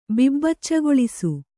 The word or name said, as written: ಬಿಬ್ಬಚ್ಚಗೊಳಿಸು